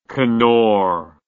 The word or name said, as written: Knorr